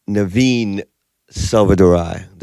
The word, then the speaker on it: Naveen Selvadurai: I'm in here with my own boy, Naveen Selvadurai.